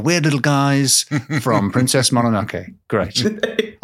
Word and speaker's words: Mononoke: I tried to say that correctly. Did I get away with it? – You did. It was okay, because I was about to say Mononoke like I would normally, but then I thought James is always correcting me.